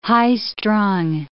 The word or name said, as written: high-strung